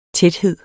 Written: tæthed

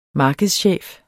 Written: markedschef

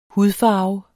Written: HUDFARVERNE

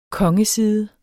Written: kongeside

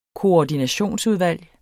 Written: koordinationsudvalg

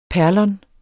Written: perlon

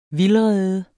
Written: vildrede

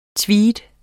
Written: tweed

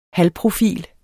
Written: halvprofil

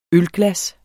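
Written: ØLGLASSET

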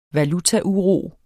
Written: valutauro

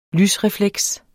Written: lysrefleks

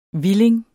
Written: hvilling

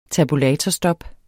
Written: tabulatorstop

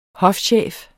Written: hofchef

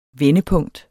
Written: vendepunkt

